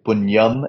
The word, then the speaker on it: punyam: So we're South Indian, so we in our sort of dialect, we'd say punyam and papam.